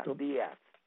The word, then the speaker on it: Díaz: pero es un apellido castellano que se llama Díaz. Tiene acento en la I, Díaz, y Z al último.